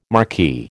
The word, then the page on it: marquee: American English